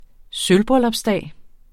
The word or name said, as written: sølvbryllupsdag